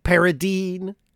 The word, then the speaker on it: Paradine: You know, when you put an E at the end of a word, usually the previous vowel is a hard one. So I, not E, Paradine.